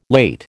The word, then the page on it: late: American English